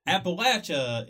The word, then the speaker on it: Appalachia: There's two ways to pronounce this area for some people. There's Appalachia Appalachia. – Appalachia. – And here's the difference. They tell whether you're from here or not. – Oh. – Appalachia is how it should be pronounced. – Appalachia. – Okay. And people that argue Appalachia are never from here.